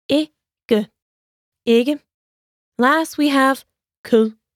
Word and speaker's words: kød: In Danish, I don't eat meat is Jeg spiser ikke kød. Let's break it down by syllable. Jeg spiser ikke kød. Now let's hear it once more. Yes, we say ku.